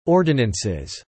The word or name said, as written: ordinances